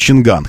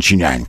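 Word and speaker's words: Changan: Вот так мы привыкли говорить Чинган, хотя нужно Чинянь как-нибудь вот так произносить.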